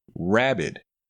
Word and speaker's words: rabid: Now, rabid has a short A and a short I sound, rabid.